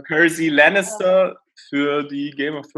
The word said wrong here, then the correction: Cersei: Ach, Cersei. Cersei, ja, so spricht man die aus.